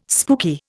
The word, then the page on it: spooky: Indian